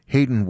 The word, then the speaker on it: Haydn: Do you suppose that when Hayden Wood's parents named him Hayden, which is spelled Haydn, they figured, oh, well, he's going to be a musician.